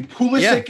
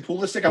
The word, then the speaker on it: Pulisic: Pulisic is the correct pronunciation. Pulisic. I want to say that right. – Yes. – Pulisic.